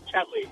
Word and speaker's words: Tetley: Is it Tetley? – Tetley.